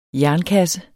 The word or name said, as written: jernkasse